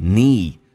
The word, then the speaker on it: need: 这是一个长元音嘴角往后咧 need 同时 D 不发音气流要阻塞